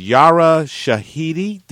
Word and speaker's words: Yara Shahidi: In real life, her name is Yara Shahidi.